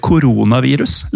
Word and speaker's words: koronavirus: I um, følge Store norske leksikon så er koronavirus, eller koronavirus, leste jeg på Språkrådet at det skal uttales, eh, er egentlig betegnelsen på en hel familie av virus.